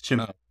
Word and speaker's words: Chimaev: I think it's Chimaev. – That pronunciation. – I'm pretty sure it's Chimaev. – Let's see. All right, here we go. Wait, where is it? – Yeah, it's Chimaev.